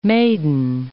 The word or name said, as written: maiden